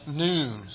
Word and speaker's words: nun: Well, this is the famous psalm of the missing none, which is how you spell the Hebrew letter N-U-N, although it's pronounced noon.